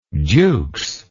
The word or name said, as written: jukes